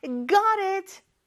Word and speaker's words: got it: 자, 근데 발음을 보면 G-O-T로 끝나지만 요 T가 T 발음으로 살아있지 않아요. 특히 미국이나 이런 데서는 got it 이렇게 real처럼 발음을 해주죠.